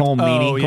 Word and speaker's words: Colm Meaney: Colm Meaney.